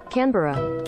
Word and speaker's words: Canberra: Canberra.